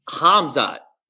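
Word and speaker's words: Khamzat: So Hamzat, am I saying it right? – Yeah, yeah, sure. – Right? Chamath, like, yeah. I'm not supposed to say the K, right? Like, no, yeah, how dare you? – It took me forever to get that right.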